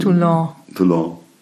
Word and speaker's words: Toulon: Toulon.